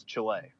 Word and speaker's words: Chile: He said, "We went to Chile," which I have been told is the right way to pronounce that, because I said Chile, and I've had several people correct me, like, don't say it like that, it's Chile. So right.